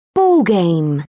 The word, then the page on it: ballgame: British English